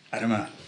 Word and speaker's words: Adama: I was so busy trying to get the emphasis on the end of it, I got the wrong beginning. – Good pronunciation. – Thank you. Adama.